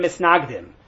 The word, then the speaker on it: Misnagdim: So the story is going to talk a lot about Hasidim and, for lack of a better term, Mitnagdim, or Hasidim and Misnagdim, for those who prefer the Ashkenazi pronunciation.